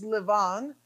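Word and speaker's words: Lavan: Now, Laban, or in Hebrew, the right way to say this is Levan.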